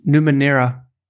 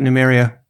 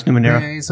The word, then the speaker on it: Numenera: Yeah, but how do you pronounce it? Like Numenera. – Numenera. All right, okay. – Or is it Numeria? No, it's Numenera.